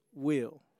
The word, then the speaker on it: Will: So it's lowercase J, U-S, space, capital W-I-L-L. And that, so my birth name is Will, not William, not Willis, not Wilbur. It is Will.